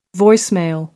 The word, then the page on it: voicemail: American English